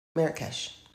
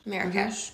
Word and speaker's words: Marrakesh: Okay, Marrakesh. Is that how you pronounce it? – Yeah, Marrakesh. – Marrakesh. Mm-hmm. Okay, got it.